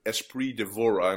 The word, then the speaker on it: Espree Devora: Okay, so I'm really, really excited today because we have a podcast today with Espree Devora, and I'm gonna see if I pronounce her name.